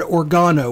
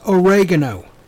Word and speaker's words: oregano: And then 10, last but not least, and because this just irritates the fuck out of me, is people that mispronounce oregano and call it organo or oregano. It is oregano.